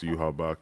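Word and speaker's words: Yhwach: it's it's spelled Yawach, but it's pronounced Yuhabak.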